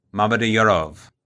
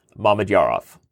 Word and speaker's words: Mamedyarov: You added some wrong emphasis on the wrong syllables. So it's Shakir, Mamed Yarov. It's literally how it's Mamed Yarov.